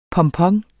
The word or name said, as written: pompon